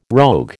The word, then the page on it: rogue: American English